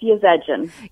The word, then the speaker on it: Yazedjian: It's Yazedjian.